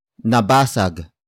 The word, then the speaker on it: nabasag: Word for word it's nabasag ko ang baso nabasag ko ang baso nabasag ko ang baso The first word is nabasag nabasag nabasag nabasag is spelled as N A B A S A G.